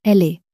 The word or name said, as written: elle est